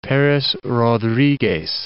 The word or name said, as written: Perez Rodriguez